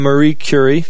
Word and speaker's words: Marie Curie: This is a quote from Marie Curie. Did I say that right? – Yep.